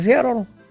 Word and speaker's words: Seattle: Seattle. – De Seattle.